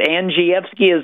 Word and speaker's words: Andrzejewski: Andrzejewski is not an easy name to say.